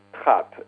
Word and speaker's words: Trappe: called Trappe, like a trap.